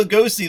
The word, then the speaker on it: Lugosi: How do you pronounce that last name? – Lugosi? – I think it's Lugosi. – It is Lugosi.